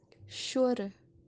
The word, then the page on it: shooru: kashmiri